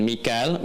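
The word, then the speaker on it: Mikael (ميكال): Jibril dengan Mikael. Mikael itu riwayat Hafiz dari Asim. Di riwayat Qalun, kemudian Warash dari Nafi'a dibacanya Mikail. Ya, makanya kita bisa baca dengan Mikael ya atau Mikail. Jelas ya? Mikael, Mikail.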